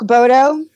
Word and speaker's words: Baudot: So Brooke Bodo, B-R-O-O-K-E, and then my last name, B-A-U-D-O-T.